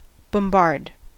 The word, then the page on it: bombard: American English